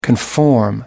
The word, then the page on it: conform: American English